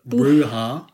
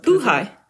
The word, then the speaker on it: Buhai: Ashley Bruha. – Yeah. – Do you know how to pronounce that? – Buhai.